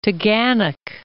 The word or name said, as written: Taughannock Falls